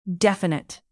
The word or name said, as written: definite